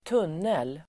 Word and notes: tunnel: [²t'un:el]

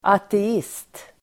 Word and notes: ateist: [ate'is:t]

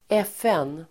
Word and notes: FN: [²'ef:en:]

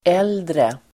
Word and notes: äldre: ['el:dre]

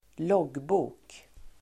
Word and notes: loggbok: [²l'åg:bo:k]

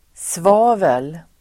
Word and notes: svavel: [sv'a:vel]

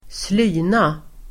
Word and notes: slyna: [²sl'y:na]

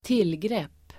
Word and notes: tillgrepp: [²t'il:grep:]

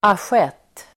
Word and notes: asjett: [asj'et:]

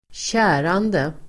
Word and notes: kärande: [²tj'ä:rande]

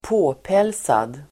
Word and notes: påpälsad: ²p'å:pel:sad

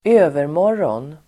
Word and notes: övermorgon: [²'ö:vermår:(g)ån]

